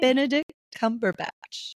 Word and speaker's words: Benedict Cumberbatch: I like that one with Bender Cumberbatch. What's his name? – Just butchered his name. – I know. Well, what's his name? – Benedict Cumberbatch.